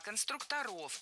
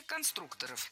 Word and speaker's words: конструкторов: Конструктора, конструкторов, это неверно. В слове «конструктор» ударение должно сохраняться на втором слоге, на «у». Поэтому конструкторы, конструкторов. Кстати, если речь идет не о профессии, а о «лего», например, то ударение все равно падает на второй слог. Только конструкторы.